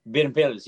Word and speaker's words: Berbilis: And did I say the name right? Because I'm like Tsaroukas, right? So we got Kalogoropoulos, Stamos, now we got Berbilis. – Yeah, Berbilis, yes.